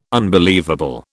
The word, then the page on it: unbelievable: British English